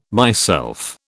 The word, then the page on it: myself: British English